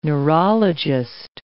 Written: neurologist

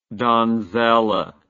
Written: donzella

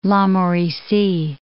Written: La Mauricie National Park

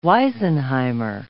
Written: wisenheimer

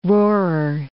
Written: roarer

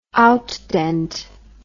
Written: outdent

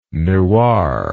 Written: newar

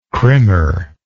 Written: crimmer

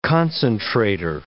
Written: concentrator

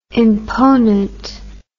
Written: imponent

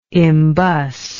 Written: embus

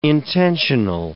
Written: intensional

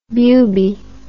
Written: bubie

